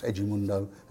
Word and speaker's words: Edmundo: We went to this Vasco da Gama friendly because they just signed Edmundo, the Brazilian national Edmundo, pronounced Edmundo, as we discovered that night.